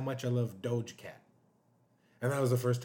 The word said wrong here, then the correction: Doja Cat: Am I saying it right? – Doja. – Doja Cat? – Yeah.